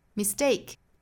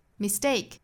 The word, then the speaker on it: mistake: Mistake, mistake, mistake. 注意这个词的重读音节呢是在第二个音节上，所以读的时候不是 mistake, 它即使是名词也是重读在第二个音节，是 mistake.